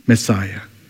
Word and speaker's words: Messiah: In Hebrew word is Masya. It came from Masa. And English pronunciation is Messiah.